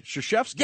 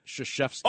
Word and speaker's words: Krzyzewski: Mike Krzyzewski. – Oh, my God. You actually pronounced his name correctly.